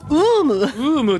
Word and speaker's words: UUUM: UUM って読む、 UUM と読むんだよって、